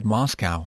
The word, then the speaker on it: Moscow: I said only half-jokingly at the time that Moscow, pronounced in English, sounds similar to us go, and so I had to go.